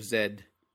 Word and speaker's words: Zed: That's Percy Fawcett in The Lost City of Zed. I guess that's the proper way to pronounce it, but everyone sounds like a pretentious asshole, unfortunately. – Well, no, it's not. That's how they say it in, like, England and Canada, right? – Yeah, yeah.